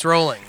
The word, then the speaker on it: Rowling: Sorry, it's rolling.